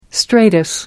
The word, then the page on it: stratus: American English